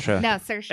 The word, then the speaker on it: Saoirse: Sorcy? It's spelled crazy, but I thought... Saoirse Ronan. None of these are real. – Saoirse.